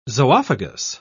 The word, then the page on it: zoophagous: British English